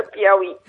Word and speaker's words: Piauí: Portanto, está a falar do Mindelo, mas é brasileira, do estado do Piauí, eu nunca consigo pronunciar esta palavra. – Piauí, é difícil, é Piauí.